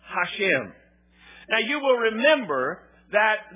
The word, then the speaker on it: Hashem: One of the names that is commonly used by the Jews is a name pronounced Hashem. It is spelled in English H-A-S-H-E-M, Hashem.